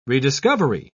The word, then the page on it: rediscovery: British English